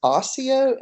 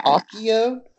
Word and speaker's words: Accio: Um, okay, so the next one is osseo, and is it accio or osseo? Is it hard? Is it? I say hard C. Hard C. Yeah. Akio? – Akio.